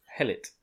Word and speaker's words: Behelit: Behelit. – It's Behelit. E-E-R-I-T. – No, no, Behirit. E-L-I-T. B-E-H-E-L-I-T.